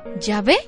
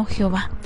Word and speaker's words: Yahvé: que es Yahvé y no Jehová.